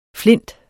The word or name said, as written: flint